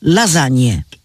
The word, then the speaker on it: lasagne: Niezależnie od pisowni wymowa jest jedna. Lasagne.